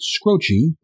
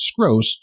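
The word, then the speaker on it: Skroce: That's spelled S-K-R-O-C-E. I've heard this gentleman's name pronounced all sorts of ways, and at first I was pronouncing it Scroce, like to rhyme with Jim Croce, but then I found a YouTube video where the man himself says Skros, so I feel comfortable sticking with that.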